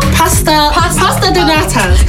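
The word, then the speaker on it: pastéis de nata: Okay, so correct me if I'm wrong. I'm probably going to pronounce this really wrong. – Yeah. – Pastries di nana? – Pasta donata.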